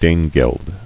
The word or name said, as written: Danegeld